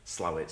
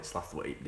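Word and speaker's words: Slaithwaite: I mean, as far as Yorkshire pronunciation goes, we'll have the whole Slawit, Slathwaite debate, or Slathwaite, if you're particularly posh, another time.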